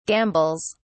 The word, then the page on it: gambles: British English